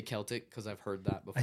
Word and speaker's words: Celtic: Celtic. Yeah, phonetically it is K-E-L-L-tick. So Celtic.